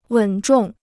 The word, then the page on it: 稳重: wěn zhòng